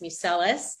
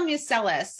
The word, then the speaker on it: Museles: It's just my name, Elise Museles. And am I missing anything? – No, I think we got it. But let's spell Museles so people know how to spell it.